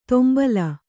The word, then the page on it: ತೊಂಬಲ: Kannada